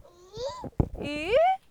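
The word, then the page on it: 鱼: 普通话; 儿童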